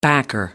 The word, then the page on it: backer: American English